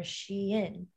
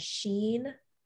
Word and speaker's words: Shein: So it's, in my, like, I'm pretty sure it is called Shein, but a lot of people pronounce it as Sheen or Shine.